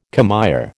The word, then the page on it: commère: American English